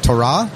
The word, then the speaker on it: Torah: Torah? – Is actually the Hebrew way of saying it.